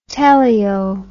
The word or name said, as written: teleo